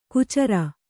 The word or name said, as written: ಕುಚರ